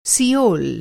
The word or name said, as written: सियोल